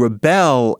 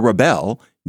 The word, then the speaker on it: rebel: Rebel, accent on the first syllable, is a noun.